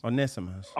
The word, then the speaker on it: Onesimus: Onesimus. – Onesimus, okay, yeah. Okay. So basically the E is silent, Onesimus.